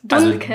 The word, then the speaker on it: Dunkes: Das schreibt man Dunkes. Dunkes. Also D-U-N-K-E-S. Dunkes. Und es hat ein weiches K in der Mitte.